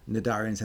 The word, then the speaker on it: cnidarians: It's got a silent C. So tenophores also has a silent C at the beginning. Cnidarians has a silent C before the N.